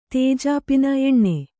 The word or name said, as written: ತೇಜಾಪಿನ ಎಣ್ಣೆ